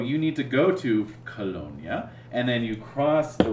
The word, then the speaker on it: Colonia: Which, speaking of pronunciation, is that Colonia? – Colonia, yep.